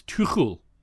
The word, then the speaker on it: Tuchel: for example, I now know how to pronounce Tommy Tuchel. It's Tuchel. Tommy Tuchel.